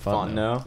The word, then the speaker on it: Fontenot: Is that how you say it? – Fontenot. Yeah, it's Cajun.